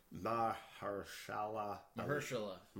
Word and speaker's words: Mahershala Ali: Specifically, Viggo Mortensen and Mahershala Ali. It's just like how it's spelled. It's just a very long name.